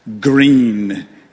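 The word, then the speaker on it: Gruene: There's a little winding Texas hill country highway that makes its way north toward Canyon Lake out of the historic town of Groon, pronounced Green in the Lone Star State.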